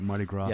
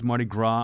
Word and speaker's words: Mardi Gras: Mardi Gras. – Yeah, the S is silent. – Yeah, it's Mardi Gras.